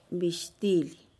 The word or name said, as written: bixtilih